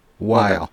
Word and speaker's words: Weill: You're also on the faculty at Cornell, and I have to ask, is it pronounced Weill Cornell Medical College? – Weill. – Okay. I worked at a public radio station hosting classical music once, and I remember being taught to say Kurt Weill, which is spelled the same way, and I thought, I wonder if that's true here as well.